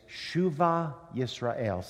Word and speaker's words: Shuva Yisrael: And our congregation, those of you who don't know or don't know how to say it, it's Shuva Yisrael. Say Shuva.